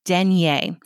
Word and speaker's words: denier: Denier. It is spelled D-E-N-I-E-R, and you can either pronounce it denier or denier. If you want to sound French, you can call it denier.